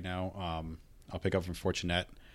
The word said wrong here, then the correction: Fournette: Hold on. You got to say his name, right? – Leonard Fournette.